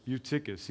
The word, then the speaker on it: Eutychus: How do you say it? Say it again. – Eutychus. – He says Eutychus. There we go.